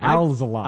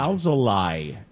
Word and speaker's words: Alzolay: Alzolay. – Alzolay. L-A-Y. Because that should be the phonetic Spanish pronunciation. Wouldn't be lay. It would be lie.